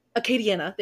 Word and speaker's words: Acadiana: Acadiana. There we go. It's Acadia, and then it's Acadiana.